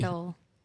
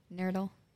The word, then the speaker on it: nurdle: Nurdle. I have never. – Heard that word. – Which one? – Nurdle. – Mm. Me either. – Rhymes with turtle.